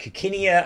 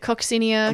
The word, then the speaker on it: coccinea: So it's Aricia coccinea, and of course coccinea means scarlet, so Yep, or you could pronounce it coccinia, cochinia. – Yeah, you could pronounce it in lots of different ways. Yep. I've always said coccinia, but I don't know.